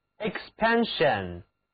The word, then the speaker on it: expansion: 好，现在得到的是 expansion， expansion，这里字母 s 还是读作 sh，因此 sion 就读作 shen， expansion 膨胀扩张。